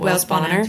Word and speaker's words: Wales Bonner: Wales Bonner.